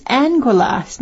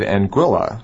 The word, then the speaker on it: Anguilla: Okay, the name of the island is Anguilla.